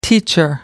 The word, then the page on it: teacher: American English